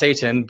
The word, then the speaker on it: seitan: Things like, it's pronounced seitan, but it's not what you think it is.